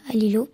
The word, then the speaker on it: Lilo: Et donc, au fur et à mesure, les associations gagnent beaucoup d'argent grâce à Lilo.